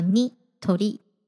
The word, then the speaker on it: Nitori: 最后是一个冷知识，我在查资料的时候发现，原来 Nitori 的前身是四鸟家具店，四是相似的四，鸟是鸟类的鸟，创业的老板呢就姓四鸟。懂日文的朋友应该就可以意会到，四鸟在日文的读音就叫 Nitori，这也就是为什么后来 Nitori 叫做 Nitori 的原因了。